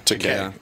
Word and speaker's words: Takei: I think it's George Takei, but I think he pronounces it. It's Takei. – Takei, yes. Like, okay. – Everyone says Takei, but yeah.